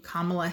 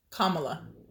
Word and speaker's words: Kamala: Kept saying Kamala, but it's Kamala. So it's Kamala. – We were putting an emphasis in the middle. – The wrong emphasis on the wrong syllable.